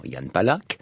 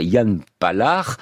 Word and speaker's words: Jan Palach: Les Français disent souvent Jan Palach. On peut dire aussi Jan Palach, hein, pourquoi pas, comme on l'a fait dans la fiction. Il semble que la vraie prononciation, chimiquement pure, pour le coup, ce soit Jan Palach, avec ce re qui ressemble à la rota espagnole, ou au CH allemand, plutôt d'ailleurs le CH allemand, c'est plus proche.